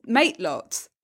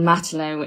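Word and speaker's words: matelot: Lots of people, I do occasionally come on and just correct it because it is the French for sailor, and it's a naval nickname for a sailor. And actually in Devon, it's usually pronounced Matlow rather than Matelow, which would be the way.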